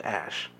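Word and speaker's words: Æ: There's a special AE letter that's pronounced Ash, and so, and the space A-12.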